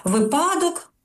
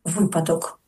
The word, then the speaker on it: випадок: Ну, якщо говорити про наголоси, коли, наприклад, кажуть «випадок», замість «випадок». 11, а треба ж 11.